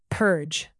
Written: purge

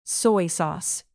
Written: soy sauce

